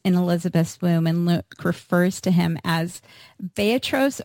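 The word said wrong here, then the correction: Brephos (βρέφος): or can you pronounce it for me? Brephos.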